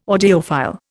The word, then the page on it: audiophile: Indian